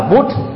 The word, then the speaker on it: Buddha: Ví dụ như là Buddha đọc ở trong tiếng Ấn Độ đó là Bút, rồi Thamma tức là chân lý đó trong tiếng Ấn Độ đọc là Thâm, còn Sangha trong tiếng Ấn Độ là Sang.